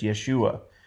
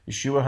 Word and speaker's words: Yeshua: If you want to say his name the way it's said in his native language, you could say it's Yeshua. Yeshua HaMashiach, the Messiah, the King, the Messiah, Jesus the Messiah, the Mashiach Nagi, the Messiah, the King.